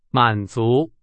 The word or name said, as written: 满足